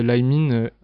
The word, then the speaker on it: Limin: Mais mon nom de rappeur, en tout cas, si un jour j'irai jouer en concert en tant que rappeur, je le prononcerai Limine L-I-M-I-N.